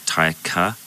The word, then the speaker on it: Taika: Like Tiger, but with a K, and instead of the ER at the end, you couldn't put an A, so it's like Taika. It's actually T-A-I-K-A, so you'd add an A between the T and the I in Tiger, so it's like Taika.